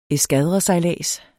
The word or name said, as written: eskadresejlads